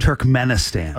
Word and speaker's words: Turkmenistan: Turkmenistan.